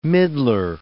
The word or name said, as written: middler